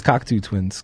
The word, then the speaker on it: Cocteau: I bet it's Cocteau Twins. Because, like, plateau.